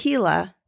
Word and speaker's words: Gila: Oh, pronounced Gila.